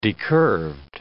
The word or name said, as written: decurved